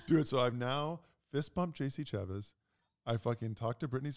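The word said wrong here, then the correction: Chasez: I do think it's Chazé. Oh, I think we're saying his name wrong. Chazé. Uh-huh.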